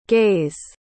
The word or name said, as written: gays